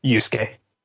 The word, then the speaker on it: Yusuke: While you can show initial interaction with Yusuke, did I get that right? Is that how you pronounce it? – Yusuke. – Yusuke. – But you're close. You're close. – It's like Asuka, but it's not pronounced that. Asuka.